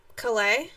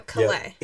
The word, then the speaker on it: Calais: And at 245, we got running back Raymond Calais. – Calais? Yes. He pronounces his name different than Campbell. – Okay, that's good to know. Calais. Yeah, he is from Louisiana, so he uses the French, you know, where they...